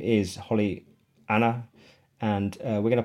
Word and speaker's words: Auna: And uh, uh her name is spelled Holly, as you would expect, and then A U N A, but pronounced Anna, A H N A, right?